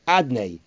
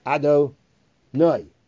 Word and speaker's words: Adonai: Therefore, the way we pronounce Hashem's name is Adnei, Adonai. Saying Hashem's name, the name that represents he is the master of everything.